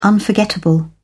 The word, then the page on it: unforgettable: British English